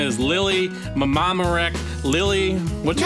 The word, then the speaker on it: Mamaroneck: Oh, Mamaroneck? – No, Mamaroneck. – No, I think it's Mamaroneck. She's no. – From... – It's from Mamaroneck.